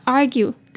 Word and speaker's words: argue: 好，这就是今天的句子。接下来我们来看今天的 pronunciation tips。首先And the researchers argue, 这边的 argue, 的 A 念大的 R 的音，后面的 R 要记得卷舌 ,R, R, 再来 GUE, 后面那个 UE 念 U 的音 ,argue, argue, argue that this pursuit,